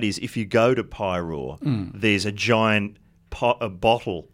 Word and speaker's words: Paeroa: They went to the planet Pyrore, I think. – Did they? How was that? It's spelled P-A-E-R-O-A, and it's a town called Pyrore.